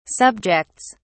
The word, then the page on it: subjects: British English